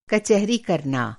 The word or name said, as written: कचहरी